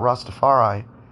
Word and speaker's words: Rastafari: And I've talked about Rastafari.